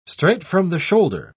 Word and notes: straight from the shoulder: British English

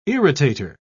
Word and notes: irritator: British English

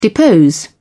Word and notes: depose: British English

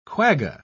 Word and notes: quagga: British English

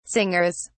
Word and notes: singers: British English

